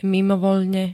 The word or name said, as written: mimovoľne